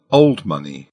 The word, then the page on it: old money: British English